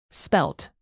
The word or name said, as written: spelt